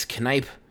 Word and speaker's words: Kneipp: I wasn't familiar with Chris Kneipp, so much so I really enjoyed him sending along a phonetical pronunciation of his name, saying it's Kneipp with a long I. Emphasis on the second syllable rhymes with can pipe.